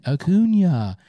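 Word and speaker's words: Acuña: You're saying it wrong put a little cue over the end, Acuna.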